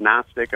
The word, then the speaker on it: Gnostic: But just when you hear the word Gnostic America, it's spelled G-N-O-S-T-I-C, kind of like agnostic without the A.